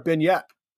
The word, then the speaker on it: beignet: Better paired with a cup, a scone or a beignet? – Beignet for sure. – Okay. See, I don't even know how to pronounce it, so that shows you how much it's French. Drop the T.